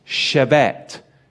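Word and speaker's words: shebet (שבט): But if we look at the word staff in the Hebrew, it is spelt shin, bet, tet, shebet, shebet.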